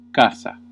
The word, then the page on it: casa: Classical; /ˈka.sa/